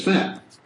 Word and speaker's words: fat: Fat은 자꾸만 이게 발음이 좀 신경을 써야 되기 때문에 꼭 그렇게 발음이 안 되고 대충 나게 되기가 쉬워요. 근데 그렇게 하면 알아듣기가 어려워지겠네요. 이게 fat 할 때 바람을 뱉어야 돼요? – 그치.